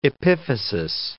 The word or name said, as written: epiphysis